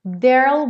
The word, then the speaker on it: there'll: Como o E final do there não é pronunciado, a contração do there com will soa there'll, there'll. É aquela passagem do R para o L que acontece na palavra mundo, world. Então você enrola a língua para o R,